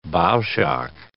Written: bow shock

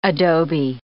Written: adobe

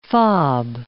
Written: FOB

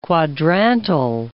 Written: quadrantal

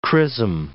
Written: chrism

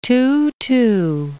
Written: too-too

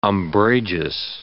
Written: umbrageous

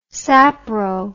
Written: sapro

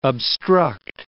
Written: obstruct

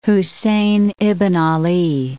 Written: Husayn ibn 'Ala

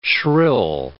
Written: shrill

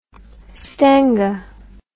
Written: stengah

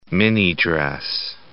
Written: minidress

